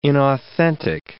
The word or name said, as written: inauthentic